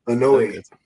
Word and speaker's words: Anoa'i: How do you say it? Anoa'i family? – Anoa'i. – Anoa'i? – Yeah, whatever.